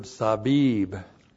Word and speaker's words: sabib: The preposition men means out from, and then the adverb sabib, sab. Sabib. S-A-B long I-B. Sabib.